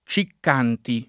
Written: Ciccanti

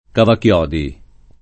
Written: cavachiodi

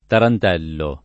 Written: tarantello

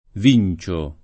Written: Vincio

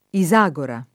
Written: Isagora

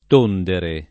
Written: tondere

